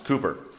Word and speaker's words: Cowper: Now, you might be thinking of the great English poet William Cowper, because that's how it's spelled, but it's not pronounced Cowper, it's pronounced Cooper.